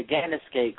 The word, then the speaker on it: Gennosuke: Okay, June 20th, 1968, Mr. Gennosuke of FMW fame was born. – Say that name again. – Gennos, and I'm butchering the pronunciation. Probably. Gennosuke? Am I saying that right, Sean? – Yeah.